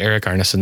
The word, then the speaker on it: Erik Arneson: Yes, you did pronounce my name correctly, Erik Arneson.